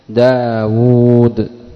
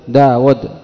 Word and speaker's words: داود: طيب، بعضهم يخطئ في قراءة داود فيقول داود داود طاووس، ينطقها كما تكتب ولا يلتفت إلى الواو المحذوفة لتوالي الأمثال، وهذا خطأ في النطق، يكون صوابا في الكتابة وخطأ في النطق.